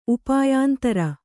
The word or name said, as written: ಉಪಾಯಾಂತರ